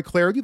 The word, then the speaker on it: Clardy: Of course, you can follow me on Twitter at Troy Clarity. The last name is spelled C-L-A-R-D-Y.